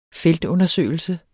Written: feltundersøgelse

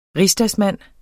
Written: rigsdagsmand

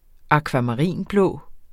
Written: akvamarinblå

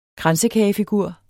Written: KRANSEKAGEFIGUR

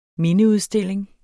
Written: mindeudstilling

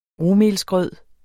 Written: rugmelsgrød

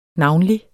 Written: navnlig

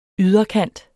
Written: yderkant

